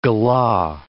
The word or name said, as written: galah